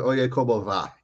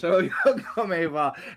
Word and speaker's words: Oye Como Va: It sounds like you're referring to Oye Como Va. – Yes, there it is. Oye Como Va.